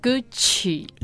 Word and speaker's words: Gucci: But lots of people read Gucci as Gucci or Gucci. – So the right pronunciation should be Gucci.